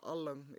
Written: Al1ng

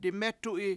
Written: dimEtu7i

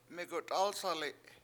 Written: megut7Asali7

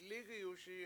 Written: lIIgiyuSi7

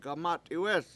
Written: gamAt71wEEs

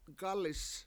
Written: gAlis

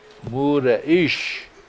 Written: mUUde7IIsh

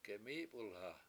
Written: gemIIp1lha

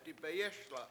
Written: dibeyES7la

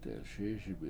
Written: delSIISibi7

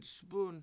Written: spUn

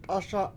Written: t7ASa7

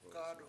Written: gAdu7

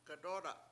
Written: gadOOda7

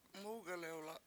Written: MUgelewla7

